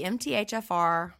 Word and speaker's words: MTHFR: Do you just say the letters? – Mm-hmm. The MTHFR mutation. – If you have the MTHFR polymorphism,